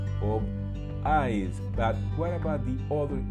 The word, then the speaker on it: Ides: In this year, in the year 44, before Common Era, Julius Caesar was assassinated in the eyes of March. And what is the meaning of eyes? This word, this term, took my attention, so I did a little research for you. Eyes, that is spelled I-D-E-S, has the meaning of the day that fall roughly in the middle of a month in the ancient Rome.